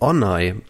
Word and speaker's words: Onay: Also ich habe übrigens erfahren, unser neuer Oberbürgermeister in Hannover, der heißt auch nicht Belit Onay, sondern Belit Onay. Das O wird kurz ausgesprochen.